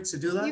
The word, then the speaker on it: Sardula: Welcome, Donna Sardula. Is that how I pronounce it? Is that right? Sardula? – You got it. Sardula. Yeah.